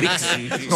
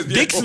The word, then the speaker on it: Disc: Compact Discs. No, Dix no. D-I-X no es. Es D-I-S-C. Disc.